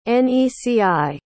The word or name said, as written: neci